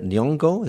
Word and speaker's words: Nyong'o: are Sally Hawking for Blue Jasmine, Jennifer Lawrence in American Hustle, Lupita Nyong'o, is that how you say her name? – Mm-hmm.